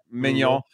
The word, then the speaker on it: Maignan: My only worry actually for AC Milan in this one is the Mike Maignan, I believe, is, I'm not sure how to pronounce his name, the goalkeeper. – Maignan.